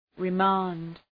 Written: remand

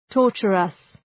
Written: torturous

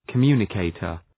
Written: communicator

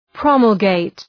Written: promulgate

